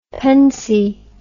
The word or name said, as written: pennsy